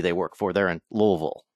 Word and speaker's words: Louisville: I think I said that right, didn't I? Louisville? – Yeah, you did.